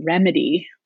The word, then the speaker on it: Rymedi: One of the coolest ones is called Remedy, which is R-Y-M-E-D-I. They have to spell it like a startup or whatever.